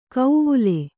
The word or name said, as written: ಕೌವುಲೆ